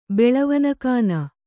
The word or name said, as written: ಬೆಳವನಕಾನ